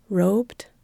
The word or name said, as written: robed